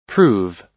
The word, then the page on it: prove: American English